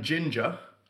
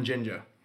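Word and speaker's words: Jinja: Ginger spelled with a J-I-N-J-A. – Oh, like Ginger. – Kind of. Ginger is apparently a place in Uganda. – Ah, but it's also like the word ninja, but with a G.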